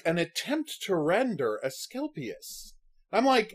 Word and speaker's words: Asclepius: So they see the Aselpus. Is that how you pronounce it? – No. – The thing that's not Codicus. Aselpus? – No, Asclepius. – Asclepius, right? And what bothers me is Asclepius. – Asclepius, thank you.